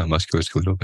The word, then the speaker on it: musculoskeletal: So after a few years of that, I started to get really bad musculoskeletal pain. How do you say that? I think you're right. Yeah, musculoskeletal pain.